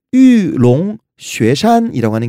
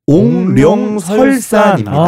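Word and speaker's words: 玉龙雪山: 그들의 성산인 유롱 쉐산이라고 하는 게 있는데 한국어 발음이 옹룡설산입니다.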